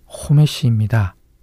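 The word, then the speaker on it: 호메시입니다 (חומש): 배에 해당하는 히브리어가 호메시입니다. 똑같은 히브리어를 몸을 달리해서 하메시라고 읽으면 숫자 5가 됩니다.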